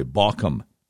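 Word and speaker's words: Baucham: His name is Vody Baucom Jr. First name is spelled with a V as in Victor, O-D-D, like double dog, I-E. Last name spelled B-A-U-C-H-A-M, B as in boy, Baucom.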